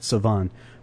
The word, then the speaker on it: Savon: And there's a correction on that pronunciation. It's simply French, not that complicated. Savon from Ali Farka Touré.